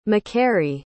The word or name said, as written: MacQuarrie